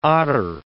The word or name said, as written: otter